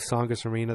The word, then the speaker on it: Saugus: I would love to go to Saugus Arena. That's how it's pronounced. The T is silent, everybody.